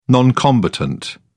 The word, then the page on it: non combatant: British English